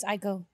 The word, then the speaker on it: Aiko: Janae Aiko. – Mm-hmm. – Did I say that right? – I think it's Aiko.